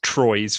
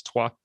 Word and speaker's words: Troyes: and I was convinced it was Troyes for years after that. And it was only when I did French properly, I was like, of course they don't pronounce it like that, and it's Trois.